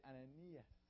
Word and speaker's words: Ananias: Everybody say Ananias.